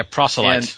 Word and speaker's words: proselytes: In the Old Testament times, there was something known as proselytes. Is that how I'm saying that right? – Yeah, proselytes.